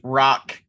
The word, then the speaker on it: Roch: I went to St. Rock Catholic School. It's spelled R-O-C-H, but of course you already know St. Rocco.